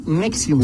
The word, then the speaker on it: Nexium: Nexiums, Nexium, se pronuncia Nexium.